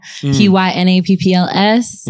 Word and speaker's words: PYNAPPLS: It's spelled with a Y, not with an I. PYNAPPLS.